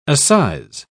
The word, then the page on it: assize: British English